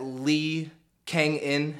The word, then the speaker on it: Lee Kang-In: I don't think I need them because have you seen the things that Lee Kang-In has been up to? I think I did that right. – Yeah, correct pronunciation, but I have not seen any news on him.